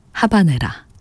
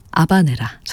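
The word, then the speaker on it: Habanera: 비제 오페라 까르멘 가운데 하바네라, 아바네라 전해드렸습니다. 고티에 카피송의 첼로, 파리 침보 오케스트라의 연주였습니다. 원어 발음에는 또 아바네라라고도 또 저희가 소개해드리곤 하는데요.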